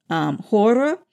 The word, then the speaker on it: Chora: Their main village, Hora, which is spelled Chora, that village is actually quite well preserved.